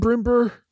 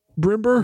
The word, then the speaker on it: Brombur: That would be B-R-O-M-B-U-R, Brimber. – Hmm. Oh, interesting. – It might be Brimbeer. I don't know how to pronounce my name.